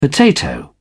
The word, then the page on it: potato: British English